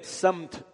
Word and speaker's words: سمت: وَلِكُلِّ وِجْهَةٌ هُوَ مُوَلِّيهَا, اور ہر ایک کے ایک سمت ہے, سمت صحیح تلفظ یہ ہے, ایک ڈائریکشن ہے, ایک رخ ہے.